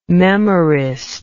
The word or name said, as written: memorist